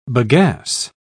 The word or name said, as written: bagasse